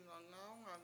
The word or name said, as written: ngawngAwngang